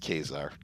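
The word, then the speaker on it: Ka-Zar: It is pronounced Kazar. K-A-Y. Yeah, because Kazar would need the dash there. You know what I mean? – Right. – So it's pronounced Kazar. Yeah.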